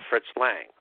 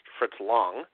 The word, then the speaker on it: Fritz Lang: With what promises to be a fairly long episode on the career and films of Fritz Lang, or properly pronounced Fritz Long.